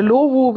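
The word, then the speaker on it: Lovoo: Und meine jetzige Freundin, die habe ich tatsächlich bei La Woo kennengelernt. – Okay, ja. Oder Lohu, wie es manche aussprechen.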